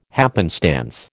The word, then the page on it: happenstance: American English